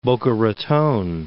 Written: Boca Raton

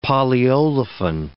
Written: polyolefin

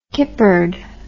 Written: kippered

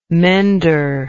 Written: mender

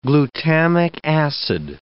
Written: glutamic acid

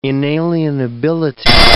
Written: inalienability

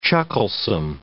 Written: chucklesome

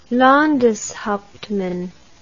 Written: Landeshauptmann

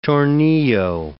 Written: tornillo

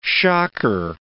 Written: shocker

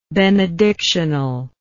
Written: benedictional

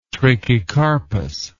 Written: trachycarpous

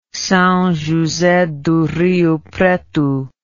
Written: Sao Jose do Rio Preto

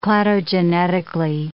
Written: cladogenetically

